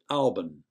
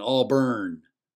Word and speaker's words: Auburn: However, it was not unusual to find locals in Sugar Hill who varied the ending and more often than not pronounced it Auburn, as in A-W-B-U-N. The city slickers who fly in and broadcast the occasional national football telecast of an Auburn game on Channel 8 would pronounce it with the burn, as in Auburn, and we always knew they weren't from around here.